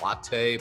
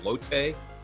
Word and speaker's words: Lotte: I hope I said that right, because I know a lot of people say kind of latte, but isn't it Lotte? – It's Lotte, yeah, and it's a pretty common thing. I'm drinking Lotte coffee in the Lotte Hotel while I play for the Lotte Giants right now, so they're all over this country.